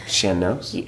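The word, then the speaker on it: sean-nós: Shen. Don't make me tell you how to pronounce Irish words. Yeah, kind of like Shen. – Yeah. – Shan Nos or Shan Nos. – Okay. – And there's two N's in there. Yeah, S-E-A-N-N-O-S.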